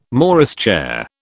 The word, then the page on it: Morris chair: British English